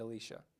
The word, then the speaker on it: Elisha: I have a niece named Elisha. That's how it's pronounced.